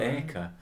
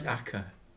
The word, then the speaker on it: Acre: It's spelled Acre, but it's Acca.